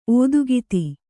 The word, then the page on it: ಓತಿಕೇತ: ōtikēta